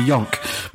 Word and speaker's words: Jonk: Magnus Nordenhof Jonk. Yonk. It'd be Yonk. Magnus with a soft J.